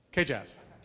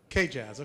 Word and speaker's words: KJZZ: Or do you go by KJazz? KJazz. Tell me the right way, dude. I'm learning. I'm new. How do you say it? KJazz.